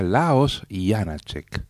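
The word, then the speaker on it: Leoš Janáček: que me decía que se escribe Leos Janácek y se pronuncia Laos Janácek.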